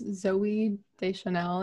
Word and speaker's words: Deschanel: It has Zooey Deschanel in it.